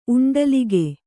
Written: ಉಂಡಲಿಗೆ

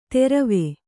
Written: ತೆರವೆ

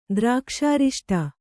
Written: ದ್ರಾಕ್ಷಾರಿಷ್ಟ

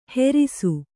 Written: ಹೆರಿಸು

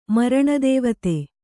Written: ಮರಣದೇವತೆ

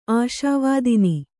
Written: ಆಶಾವಾದಿನಿ

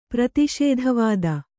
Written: ಪ್ರತಿಷೇಧವಾದ

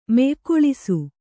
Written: ಮೆಯ್ಕೊಳಿಸು

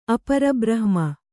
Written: ಅಪರಬ್ರಹ್ಮ

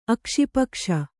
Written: ಅಕ್ಷಿಪಕ್ಷ

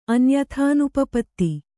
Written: ಅನ್ಯಥಾನುಪಪತ್ತಿ

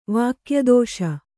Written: ವಾಕ್ಯದೋಷ

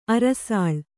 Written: ಅರಸಾಳ್

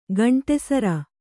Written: ಕಂಠಿಸರ

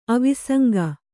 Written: ಅವಿಸಂಗ

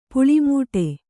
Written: ಪುಳಿಮೂಟೆ

